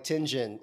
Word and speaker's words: Tianjin: They're always like, you know, I'm like Tianjin. It's just tin like the metal, gin like the drink, because I anglicize it like a motherfucker.